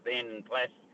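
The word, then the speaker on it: Vanden Plas: Yes, look, I've got a Vanden Plus PLAS. It's pronounced Vanden Plus 1100.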